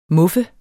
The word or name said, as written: muffe